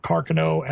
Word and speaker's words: Carcano: And you could actually look down and see where he allegedly aimed the Mannlicher Carcano, or Carcano as it is more properly pronounced,